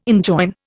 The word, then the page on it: enjoin: Indian